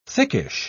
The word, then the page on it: thickish: British English